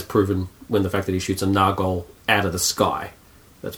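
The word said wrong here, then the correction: Nazgûl: A flying Nargol, or Nargul, how do you pronounce it? – Nazgul, that's what I'm thinking of. Nazgul, you know what I'm trying to say.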